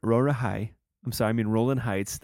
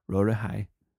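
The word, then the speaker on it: Rowland Heights: I was in Roland Heights. That's how they say it. Roland High. I was in Roland Heights. Roland Heights is very, very heavily Asian.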